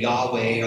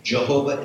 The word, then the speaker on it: Yahweh: Jehovah is a mispronunciation. It's a misunderstanding of the Hebrew vowels that are missing, the consonants YHWH. And back in the old days, like the 14th, 1600s, they inserted the wrong vowels there, a misunderstanding.